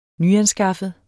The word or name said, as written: nyanskaffet